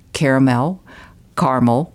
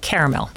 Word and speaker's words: caramel: Well, let's start with the caramel. Both caramel and caramel are correct pronunciations. There's only one spelling of the word, but both pronunciations are correct. As for my name, it is confusing for people.